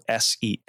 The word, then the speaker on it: Senderovich: My last name is spelled Senderovich. So just Sender and emphasis on the O and then just the Vich.